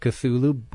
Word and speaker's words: Cthulhu: When you see C-T-H-U-L-H-U, how do you pronounce that? Cthulhu is where most people seem to settle.